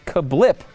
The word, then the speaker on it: Cablp: It's actually apparently pronounced Kablip, spelled though C-A-B-L-P.